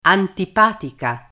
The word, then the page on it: antipatica: Italian